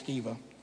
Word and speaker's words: Sceva: If it's a long E, it's Sceva.